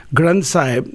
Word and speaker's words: Granth Sahib: Granth Sahib, that's okay.